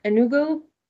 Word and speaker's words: Enugu: Dr. Akunyili grew up in Enugu.